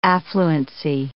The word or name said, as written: affluency